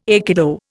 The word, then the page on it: aikido: Indian